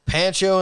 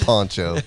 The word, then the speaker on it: Pancho: Pancho and Lefty. That's what I'm calling it, damn it. – It's Poncho. – It drives me crazy that it's spelled like that.